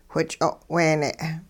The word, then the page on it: which'o'weene': hupa